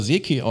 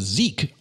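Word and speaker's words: Zeke: Ich habe sie immer Seke ausgesprochen, aber sie werden nur Sieg ausgesprochen.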